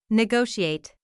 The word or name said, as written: negotiate